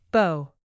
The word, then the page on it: bow: weapon